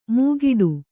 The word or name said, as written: ಮೂಗಿಡು